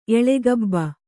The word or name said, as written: ಎಳೆಗಬ್ಬ